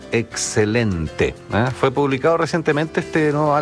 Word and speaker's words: XCLNT: Así se llama, pero en el disco lo escriben con las letras XCLNT. Se pronuncia excelente, el nuevo disco de Los Tres.